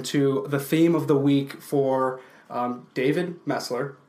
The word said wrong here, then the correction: Meltzer: Ooh, Meltzer. – What's his name? – Meltzer. I will get his name right.